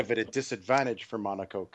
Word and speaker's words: Monaco: in the whale-heeled in Monaco. Is that how it's pronounced? – Not Monaco. – It is if you're Stirling Moss. Otherwise, it's Monaco, generally.